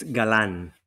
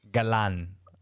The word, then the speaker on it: Galan: Is is it Gallon or? Uh, it's Galan. Galan.